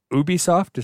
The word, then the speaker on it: Ubisoft: And so the reason that they went to Ubisoft, is that how you pronounce it? – Yeah.